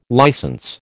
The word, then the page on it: licence: American English